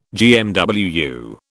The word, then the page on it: GMWU: British English